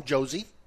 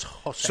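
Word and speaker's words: José: whose name is spelled J-O-S-E? – Jose. – What do you call him? – Jose. – That's the correct pronunciation. You don't call him Josie.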